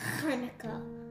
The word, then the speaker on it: Hanukkah: This is how you say Hanukkah correctly. Hanukkah.